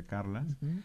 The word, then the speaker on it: Carles: Y también porque, bueno, porque saben, una vez la anécdota con Carlas, que se escribe Carles y se pronuncia Carlas, es que Fabián le regaló un compilado de discos de Atahualpa Yupanqui y Carla dice, no, acá faltan tres discos.